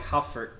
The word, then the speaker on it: HU4D: HU4D is said like Hufford. Whoops. Said pronounced like Hufford.